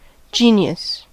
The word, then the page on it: genius: /ˈdʒiːnɪəs/